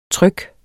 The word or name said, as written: trykken